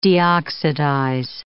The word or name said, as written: deoxidize